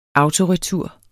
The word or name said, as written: autoretur